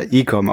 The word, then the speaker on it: ICOM: Ich habe mich nämlich immer schon gefragt, wie spricht man das Ganze eigentlich aus? Icom oder Ecom? Und du scheinst es ja Ecom auszusprechen. Sehr gut.